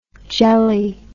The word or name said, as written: gelly